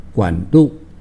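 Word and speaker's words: 管辂: 大家讲的是一个算命师，叫做管路。 – 管路，电线的管路吗？ – 这个管就管子的管，管仲的管。路呢比较难写一点，那是车字边。 – 车字边。 – 对，然后右手边一个个。 – 嗯，左边是一个车子的车，右边是一个各自为政的个。 – 对，那这个是读作路，管路。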